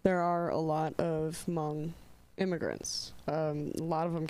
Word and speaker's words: Hmong: Oh, H-M-O-N-G. – Okay. – Hmong people. The H is silent.